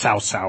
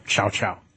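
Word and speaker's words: Cao Cao: The general who made the uncharacteristically poor showing at Red Cliffs was a warlord named Cow-Cow. Sometimes his name is pronounced Cao-Cao or Chow-Chow.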